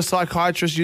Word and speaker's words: psychiatrist: Why can't you hear a psychiatrist using the bathroom? Because the P is silent.